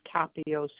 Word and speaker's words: Capio: It's Dora, and the last name is Capio, C-A-P-I-O.